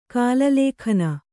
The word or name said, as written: ಕಲಲಕಣ